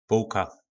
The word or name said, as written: polka